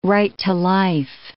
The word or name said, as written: right-to-life